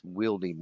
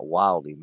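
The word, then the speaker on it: Wildey: And yes, I did say that correctly. It's Wieldy Magnum, not Wildy Magnum.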